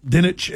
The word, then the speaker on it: Dinich: Where they had that Heather Dinkich, or however you pronounce her last name. – Dinich and Paul Feinbaum were down there.